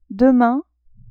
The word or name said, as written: demain